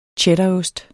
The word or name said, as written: cheddarost